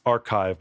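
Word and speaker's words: arXiv: I guess it's supposed to be pronounced archive, but it's spelled with an X instead of a CH for like cool science reasons.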